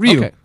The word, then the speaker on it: Ryu: Ryu, how do you pronounce that? – Ryu. – Okay, Ryu. – That's correct, Ryu.